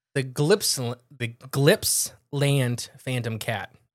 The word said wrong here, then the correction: Gippsland: But it's G-I-P-P-S Land. – Gippsland. Yeah, Gippsland Phantom Cat.